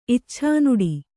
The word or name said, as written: ಇಚ್ಛಾನುಡಿ